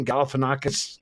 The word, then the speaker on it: Galifianakis: Galifianakis.